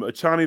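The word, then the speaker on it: Achane: Okay, you got um, uh, how how do you pronounce my man's name? Akane, Achani. – Um, Achani, the receiver for the Miami Dolphins.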